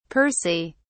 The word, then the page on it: percy: British English